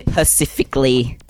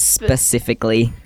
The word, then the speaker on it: specifically: And pacifically. Come on, guys. It's specifically.